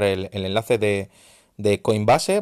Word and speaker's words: Coinbase: Yo uso eh, Coinbase, ¿vale? O Coinbase, si lo pronuncias bien en inglés.